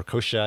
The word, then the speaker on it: kochia: I think of cochia, right? – Or kochia, I think it is the better pronunciation.